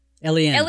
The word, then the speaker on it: Eliane: Eliane. Because on Capella 4, the 10 tribes all have two vowels at the middle that you say separately, like Ma'ab and Eliane and Tier.